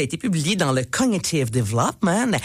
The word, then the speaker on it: Cognitive Development: Cognitive Development.